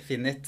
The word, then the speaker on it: Finit: Ja, da sitter jeg her i Finit, eller hvordan uttaler du det? Finit? – Nei, riktig. Finit.